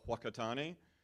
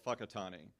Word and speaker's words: Whakatane: So it's called Whakatane. And so here, it sounds like it catches you off guard, but if you say Whakatane, it's offensive because that's not how it's pronounced.